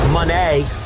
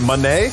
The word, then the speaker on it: Monet: Is it Monet? – Monet. – Monet. Monet. Not even, it's not money. – Monet. – It's not Monet. It's Monet. Monet.